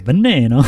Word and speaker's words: Veneno: Veneno. Say it right. – That thing. – Say it right. – Veneno. – There you go.